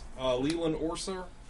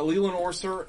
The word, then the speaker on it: Leland Orser: It stars Leland Orser. I said it right. – Yeah, you're right. – Leland Orser and Mary Elizabeth Winstead.